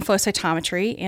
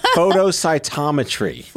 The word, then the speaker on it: flow cytometry: And I was supposed to be doing flow cytometry, and I fell into this microscope. – Wait, you keep throwing syllables here. Photocytometry.